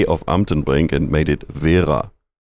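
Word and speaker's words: Wera: The correct pronunciation in German, and I'm German, is Vera. I know Anglophile people will normally say Vera because they roll the R, but that's fine with us. But Vera is the correct word.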